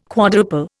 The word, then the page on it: quadruple: Indian